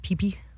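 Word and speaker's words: Phi Phi: It's like PHI Islands, but it's pronounced PP.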